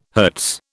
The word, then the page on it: Hz: British English